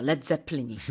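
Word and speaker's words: Led Zeppelin: 레드 제플린이. 그런데 사람들이 발음을 리드 제플린이라고 할까 봐 그냥 스펠링을 LED라고 썼답니다.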